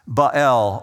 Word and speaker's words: Baal: And I find God's humor in the midst of this, because the God of the Canaanites is Baal, God small g. Baal, or Baal as somebody pronounces it.